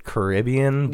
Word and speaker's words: Caribbean: In Walt Disney's Pirates of the Caribbean movies, the word Caribbean always is pronounced Kerr-I-B-Un.